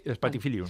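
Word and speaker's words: Spathiphyllum: Spatifilo, y cuando... ¿Lo he dicho bien? – Sí, Spatifilium. Bueno, sí.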